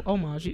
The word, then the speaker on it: homage: Oh, homage. It's a silent H.